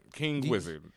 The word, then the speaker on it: KNGWZR_D: I think it's King Wizard. – There you go. – Yep, King Wizard.